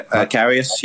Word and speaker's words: Karius: Yeah, Karius.